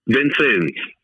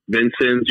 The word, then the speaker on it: Vincennes: Your dad follows the high school career, and I did not know how to pronounce this. Maybe you do. Vincensis, is that correct? Junior college? – Vincennes. – Okay. – Vincennes University in Indiana.